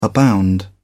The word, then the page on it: abound: British English